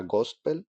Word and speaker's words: Ghostpell: llama Gospel, eh, se escribe G-H-O. S-T-P-E-L-L, nadie se va a quedar de escucharlo porque no sepa escribirlo.